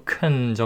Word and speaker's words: can: 자, can을 can이라고 안 하고 큰 정도로 발음한다고 했습니다.